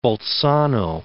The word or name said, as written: Bolzano